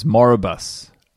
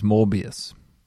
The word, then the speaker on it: Morbius: I think it looks it's like Morbius. Morbius. Right? In my head, I've always been saying it as Moribus and then I had to look it up today, and it was Morbius.